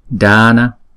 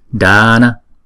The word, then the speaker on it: Dana: Chữ này chúng ta đọc là Dana, Dana. Chữ D á, thì chúng ta để cái đầu lưỡi ngay cái bên trong của hàm răng trên á, chúng ta đọc là D, D, Dana.